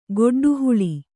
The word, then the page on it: ಗಡ್ಡೆಹುಲ್ಲು: gaḍḍe hullu